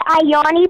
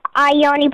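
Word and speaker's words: Iyanni: Facebook at Ayani Pradia. Ayani Pradia, it's spelled with an I. It's I-Y-A-N-N-I. Pradia is spelled P-R-A-D-I-A.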